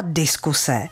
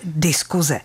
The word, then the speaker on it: diskuse: A spisovná výslovnost slova diskuse byla až donedávna diskuse. Akorát, že to nikdo nerespektoval a všichni pěkně jako vy moderně začali říkat diskuze. – Takže teď jste do toho ještě zapletla, tak jak se to může vyslovovat, nejenom psát. – Všichni říkají diskuze, takže jazykovědci řekli dobře, spisovná výslovnost je i diskuze, řekli.